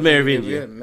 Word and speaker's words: Merovingian: And they meet the Merovingian. Did I say that right? – I believe so. The Merovingian, yeah. – The Merovingian.